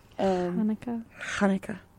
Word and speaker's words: Hanukkah: Hanukkah.